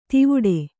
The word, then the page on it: ತಿವುಡೆ: Kannada